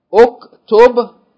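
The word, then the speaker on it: Uktub (اكتب): Uktub, the Dhamma of the first word is for Hamzatul Wasl. We pronounce it because there's nothing before. So we're saying Uktub.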